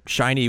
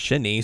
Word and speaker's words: shiny: I mean, I always thought shiny was pronounced shinny, so.